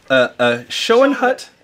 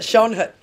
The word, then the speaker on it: Schoenhut: And this one over here, Schoenhut. Yep. Am I saying that right? – Yep, you got it. Schoenhut.